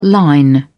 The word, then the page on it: line: British English